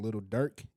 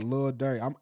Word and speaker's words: Lil Durk: He dropped a new song called Leave the Club, featuring Lil Durk and Glorilla. He also announced that his album, which is titled Love Sick. – I hate that you pronounced the T's in Lil Durk.